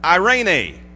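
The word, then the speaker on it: eirene: Well, in the New Testament Greek word equivalent from shalom is a little word, once again, that sounds nothing like peace, but it's pronounced irene.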